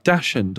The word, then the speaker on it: Dachshund: Dachshund or Dachshund? – Well, it's a German breed, so I think it's Dachshund. – Right, okay. – But some people call them Dachshunds.